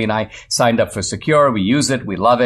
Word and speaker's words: Sekur: Secure is the better way. It's spelled S-E-K-U-R.